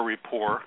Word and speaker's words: Report: I've seen him, for example, on either The Daily Show or the Colbert Report, with the silent T at the end of both words.